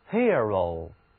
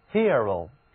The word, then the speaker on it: hero: 这里的字母 E 读作 ear， hero。如果你看到字母 E 出现在一个重读开音节中，那么它后面还加一个发音的字母 R，一般呢都要读成 ear， hero。